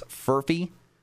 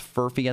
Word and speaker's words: Furphy: Johnny, I think it's Furphy. It's how you say it. It looks like Murphy, but instead of the M, it's the F. So it's Furphy, I think it is.